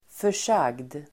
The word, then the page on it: försagd: för_s'ag:d